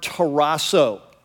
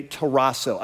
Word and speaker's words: tarasso: So the word troubled in Greek is the verb tarasso, okay, tarasso. I just like the sound of it. And here's how it's spelled in English, T-A-R-A-S-S-O, okay? T-A-R-A-S-S-O, tarasso.